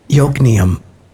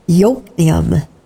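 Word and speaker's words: Yokneam: But it starts with a J, and so you might say Jokneum. – Yeah. Wow. Okay. I'll have to remember that stuff. How do you say that? – Jokneum.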